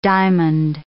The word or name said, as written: diamond